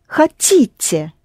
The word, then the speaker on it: хотите: So you say, я хочу, but вы хотите, stressed on the ending.